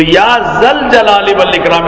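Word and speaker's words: يا ذا الجلال والإكرام: تو یا ذل جلال والاکرام اس کا صحیح تلفظ ہے، یا حیو یا قیوم، یا ارحم الراحمین، یا ذل جلال والاکرام.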